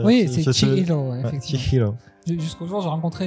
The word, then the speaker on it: Chihiro: m'avait dit Mais tu sais, Chihiro, ça se prononce Chihiro.